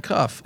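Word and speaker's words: Cuffe: Kevin Cuff. Silent E.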